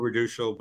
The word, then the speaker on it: Raduchel: So normally I would do reducial, but don't worry about it.